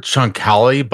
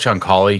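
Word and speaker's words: Chancalay: I've heard Chunkali, but I also heard someone pronouncing it differently in the press box. So let's just go with Chunkali because I think that is the dominant pronunciation, which is probably influenced by how he pronounces it.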